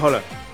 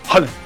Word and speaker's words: Haaland: Bueno, Alfredo González, comentarista de Fórmula Deportes, ¿cómo se pronuncia? – Holland. – Holland. – Holland. – Es con O. – Y es rápido, es Erling Haaland. – Haaland. – Así es su nombre.